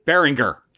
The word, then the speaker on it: Berenger: Tom Bergeron. I almost said Bergeron. How do you say his name? Berenger. – Yeah, Tom Berenger. – Berenger, yes. Charlie Sheen, Wesley Snipes is in it.